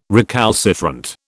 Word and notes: recalcitrant: British English

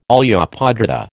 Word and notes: olla podrida: American English

